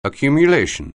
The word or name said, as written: accumulation